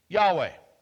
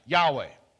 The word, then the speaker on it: Yahweh: Yahweh. That's how we pronounce it. Yahweh.